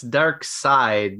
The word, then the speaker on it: Darkseid: It's Dark Side, no E accent on the...